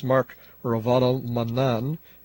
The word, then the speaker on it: Ravalomanana: So the ex-president is Mark Ravalomanana. It seems the final A is silent, and the new one is Andri Rajoelina.